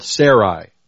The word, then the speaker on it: Sarai: Now Sarai, notice it's spelled Sarai. Okay? Sarai. And we can see her name right here in the Hebrew. Right there. S-R-Yod. Shin, Resh, Yod, Sarai. And this is a vowel pointer that tells you how to say it. Sarai.